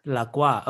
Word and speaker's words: La Croix: How do you pronounce the carbonated, slightly barely flavored soda, that is two words that are in French? – Oh, La Croix? – La Croix. Okay, you say that.